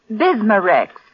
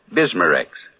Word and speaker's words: Bismarex: Bismarex. Is that how you say it? – That's right, ma'am. B-I-S-M-A hyphen R-E-X. Bismarex.